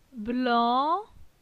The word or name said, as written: Blan